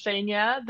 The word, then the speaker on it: Zhenya: So it's Zhenya, the same as you just pronounced it.